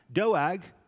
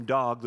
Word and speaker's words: Doeg: Doeg, as his name is pronounced, but you can just call him Dog, the rest of us do that know him well.